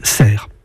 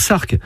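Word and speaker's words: Sercq: Cela montre que la prononciation originelle était Cerques, mais que dès cette époque, la consonne finale n'a plus été articulée et que l'on a dit ensuite Cerre.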